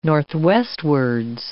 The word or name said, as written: northwestwards